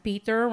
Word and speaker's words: Pietr: It's Peter spelled P-I-E-T-R, so you know he is German. – Yeah. – Peter rhyming with Dieter.